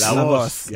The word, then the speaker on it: La Voz: We've seen you go to, I always mispronounce it, La Voz. – La Voz, yeah.